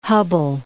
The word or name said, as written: Hubble